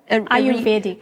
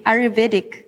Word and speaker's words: Ayurvedic: And I mean, in the work that you do in your practice, you combine kind of the, you'll correct me on the pronunciation, A-Y-R-E-D. What is it? – Ayurvedic. – Ayurvedic. Yes.